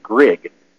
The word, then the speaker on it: Grigg: And you can find that by going to a search engine and typing in Pro Libertate and Grig, P-R-O-L-I-B-E-R-T-A-T-E, and then my last name is spelled G-R-I-G-G. It's Griggs without the S.